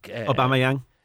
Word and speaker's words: Aubameyang: Aubameyang. – Exactly. my nemesis. You actually nailed it there. – You play for Arsenal. – Aubameyang.